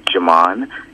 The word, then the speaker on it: Jamon: My email address is Jamon.